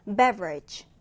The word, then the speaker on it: beverage: 첫 음절에 강세를 두고 발음 주의해서 읽어봅니다. beverage, beverage.